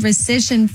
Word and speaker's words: rescission: So another player, Cincinnati Bengals wide receiver Ja'Marr Chase, also received good news on the fine rescission front. – The rescission front? – Recession? R-E-S. – Rescission. – I said it right.